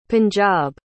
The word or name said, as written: punjab